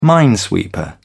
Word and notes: minesweeper: British English

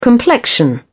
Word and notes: complexion: British English

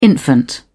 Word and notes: infant: British English

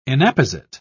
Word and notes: inapposite: British English

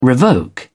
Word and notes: revoke: British English

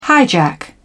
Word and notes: hijack: British English

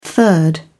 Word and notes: third: British English

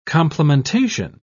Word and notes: complementation: British English

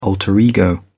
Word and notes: alter ego: British English

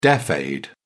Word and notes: deaf aid: British English

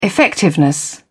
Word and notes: effectiveness: American English

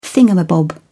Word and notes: thingamabob: British English

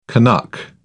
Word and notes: canuck: British English

